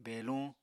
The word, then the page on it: beluñ: Apatani